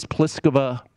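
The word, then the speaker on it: Plíšková: But as far as tomorrow, it is Pliskova, to pronounce that correctly, against Ashley Barty of Australia.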